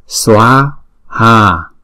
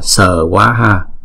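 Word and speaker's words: svāhā: Chứ chúng ta không đọc là sờ quá ha, mà chúng ta đọc là xóa ha, đó.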